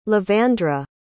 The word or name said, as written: livandra